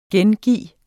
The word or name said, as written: gengive